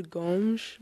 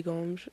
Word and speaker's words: Gomes: Because I know that the proper pronunciation is Gomes. – Mm-hmm. – but the Americanized version is Gomes. So do you have a preference? – I prefer Gomes, but I don't know. You say it correct, so you can say Gomes.